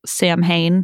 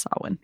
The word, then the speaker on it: Samhain: and I pronounced it Samhain because that's how it's spelled. Mm-hmm. And no one corrected me, Em. And then the next week, my Twitter was really a mess. So it was bumping. – It was bad. And it still gets that way when people get to that episode. So in case you're wondering, that word, you may have seen it in writing, but it's pronounced Samhain.